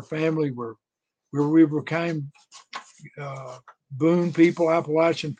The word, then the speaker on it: Appalachian: They put me in a little office and taught me how to pronounce Appalachian. This is Appalachian, Lash, Appalachian.